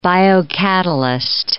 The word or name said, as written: biocatalyst